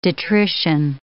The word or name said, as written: detrition